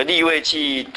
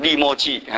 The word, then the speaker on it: 利未记: 这个利未记读成利末记，哎，这个哎，敌土马念成敌士马哈，这个我都哎，那我都有没有什么时间纠正哦，先让你们注意一下，因为还是有差的。